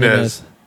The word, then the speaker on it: Nunes: Is her name Nunes or Nunes? – Nunes. Nunes. It's Nunes.